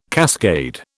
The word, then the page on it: cascade: British English